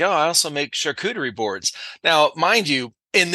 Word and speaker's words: charcuterie: And then this charcuterie, I was like, I don't even know what a charcuterie board is.